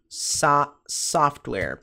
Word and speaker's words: software: Software, pronúncia software, software, software, software.